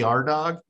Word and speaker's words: Ardagh: Um, and uh, anyway, it's pronounced A R D A G H, not R dash D O G.